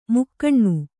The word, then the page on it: ಮುಕ್ಕಣ್ಣು: mukkaṇṇu